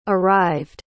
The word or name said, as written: arrived